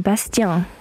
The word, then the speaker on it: Bastian: Man müsste doch auf Französisch eigentlich aussprechen. Nein, Bastian. Bastian. – Bastian Baker.